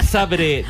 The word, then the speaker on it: Zabrze: Górnik Zabrze de Polonia 2, Mónaco 3, ganando el equipo monegasco. ¿Por qué sabes que es Zabrze? ¿Por qué no? ¿Por qué pronuncias Zabrze? – Porque es así.